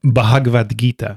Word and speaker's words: Bhagavad Gita: Bhagavad Gita. – Exactly. That. – So or in English, you would say the Bhagavad Gita, but the B-H is Bhagavad Gita.